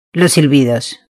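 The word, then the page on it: los silbidos: Spanish (Spain)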